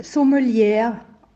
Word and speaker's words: Sommelier: Man spricht jetzt Sommelier aus, also das E wird nicht ausgesprochen am Ende.